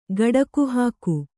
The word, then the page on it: ಗುಡುಗುಹಾಕು: guḍugu hāku